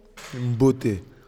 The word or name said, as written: mbote